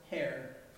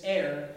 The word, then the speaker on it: heir: I used to think it was pronounced hair, because there's an H. I didn't realize it was actually a silent H. It's heir, right?